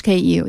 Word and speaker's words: KU: How do you KU, the pronunciation for your company? Kyo? – In English, KU, yeah.